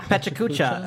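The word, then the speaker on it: PechaKucha: I guess the next Pikachu or whatever, Pekachu, how do you say it? – Pechacucha. – Oh, yeah, that's right.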